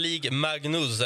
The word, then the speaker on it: Ligue Magnus: Alltså, den heter, alltså, heter den League Magnus, eller hur, hur uttalar man det? Här? – Ja, ja, det stämmer.